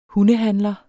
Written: hundehandler

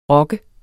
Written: ROKKEN

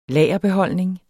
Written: lagerbeholdning

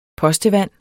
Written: postevand

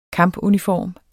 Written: kampuniform

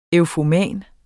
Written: eufoman

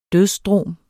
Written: dødsdrom

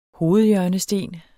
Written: hovedhjørnesten

